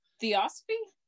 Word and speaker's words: Theosophy: Theosophy? – Thank you.